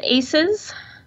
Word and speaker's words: ACEs: It is pronounced just simply ACEs, and we usually make the S small on the ACEs because it stands for Adverse Childhood Experience, and the S just makes it plural, ACEs.